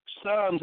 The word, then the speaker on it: Psalms: real quick with that 11, but Psalms, that's why they got it with that P S in the beginning, that silent P, because that P made Psalms now 13.